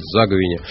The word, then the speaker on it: заговение: Сегодня заговение перед наступающим Успенским постом, даже если вы считаете, что в этом слове другое ударение. У меня бабушка так говорила, я так говорю и буду говорить заговение, который проходит с 14 по 27 августа.